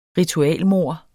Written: ritualmord

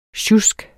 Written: sjusk

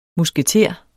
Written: musketer

